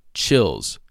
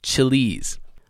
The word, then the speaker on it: Chiliz: I was saying it was chills, but it's not. It's called chilies. The spelling of chilies is C-H-I-L-I-Z. It looked to me as there was two L's in there, chills, so I was pronouncing it chills, but it's not. It's chilies.